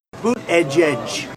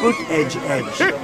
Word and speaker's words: Buttigieg: Boot edge edge. You know what you call them? Boot edge edge.